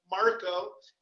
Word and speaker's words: Marco: If anybody is listening and they want to find the Steve Mora Marco conspiracy, my name is spelled Mora, M-O-R-A, and then Marco, M-A-R-C-O.